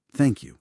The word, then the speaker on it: thank you: Please, thank you. – Importantísimo. Y ojo con la pronunciación de thank you. Thank you. Esa TH que a los hispanohablantes, bueno, nos cuesta.